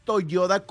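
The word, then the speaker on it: Toyoda: Y es Toyota con D de dedo, ¿eh?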